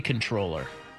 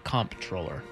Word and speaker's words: comptroller: By the way, there's a whole NPR story on, it's actually controller. – What? – It's not comptroller, even though it's spelled that way.